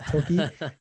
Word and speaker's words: Tilke: It's Tilke. I checked the pronunciation because it looks like Tilke. – Smart. – But I did check the pronunciation. It's Tilke. and he has designed right now almost a third of all of the tracks that we currently see in the F1 circuit.